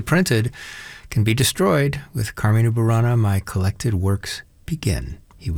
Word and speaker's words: Carmina Burana: Carmina being the plural of Carmen, Latin noun for song, hence the first syllable stress, Carmina Burana.